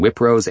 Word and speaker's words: Wipro: If you had to pick an environment that is the opposite of the Flanders trenches, you might pick the WIPRO, pronounced Wipro, call center in Bangalore, India.